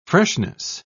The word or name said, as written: freshness